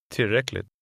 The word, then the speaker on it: tillräckligt: The final T is pronounced very quickly. – Tillräckligt.